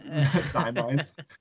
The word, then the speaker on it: Ximines: Zimines. It's spelled X-I-M-I-N-E-S. So it's hard to pronounce, okay? Zimines.